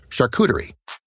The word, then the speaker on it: charcuterie: The correct pronunciation is charcuterie. It's a French term describing prepared or cured meats.